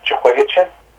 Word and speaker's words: Jehoiachin: You see, for instance, Jehoiachin? Am I pronouncing that right? – Yeah.